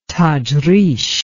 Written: tajrish

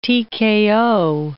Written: TKO

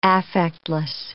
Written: affectless

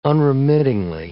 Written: unremittingly

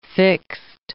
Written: fixed

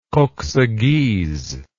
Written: koksaghyz

